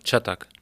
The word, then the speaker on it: Çatak: Ilka Schattak? – Ja, Schattak.